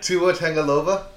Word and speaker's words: Tua Tagovailoa: Tua Tagovailoa.